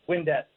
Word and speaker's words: Windett: Did I pronounce your last name properly? How do you pronounce that? – Windett. – Good. I did.